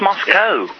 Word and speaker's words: Moscow: and New York is just about the distance between Madrid and Moscow. So the variance in the United States... – Moscow. It's Moscow. C-O-W.